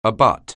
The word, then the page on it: abut: anglais